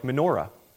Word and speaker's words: menorah: Now, you can say that you know a Hebrew word if you know that term, because that's what the Hebrew word sounds like. It sounds like menorah. At least that's what we think it sounds like. And that's how I learned to pronounce it anyway.